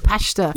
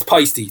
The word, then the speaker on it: Paiste: Which I would always have read as kind of the Irish, like pashta. – Yeah. – Which is pashta. Pashta. – Pashta. Pasty. There's rates, but it's Peisty, it's pronounced, yeah.